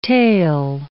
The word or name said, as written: tail